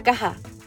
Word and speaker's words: cwtch: Aunque se escribe CWTCH, al parecer se lee algo así como Caja. Yo sé, no tiene ningún sentido, pero todo tiene un porqué. Es una palabra galesa que parece impronunciable, pero tiene un significado tan bello que me gustaría que reflexionáramos sobre ella.